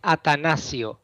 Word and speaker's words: Atanacio: Sure, it's Atanacio.